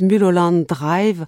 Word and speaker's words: Mulholland Drive: Euh, parlons d'un film que beaucoup ont vu, je vais le prononcer à la française, Mulholland Drive de David Lynch en 2001.